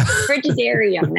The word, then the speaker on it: Frigidarium: But another feature that they added to this particular ship, and I'm probably going to butcher this name, the Frigidum. It's the ice room, I call it. – If that's not a cold area, then they've misnamed it. Yeah. – Frigidarium. That's it. Frigidarium.